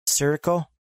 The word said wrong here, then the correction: Sicario: Oh, yeah. Sicario. – Sicario. Thank you.